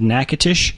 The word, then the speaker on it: Natchitoches: And that is not pronounced Natchitoches. – Exactly. It is not pronounced in any way, shape, like it is spelled.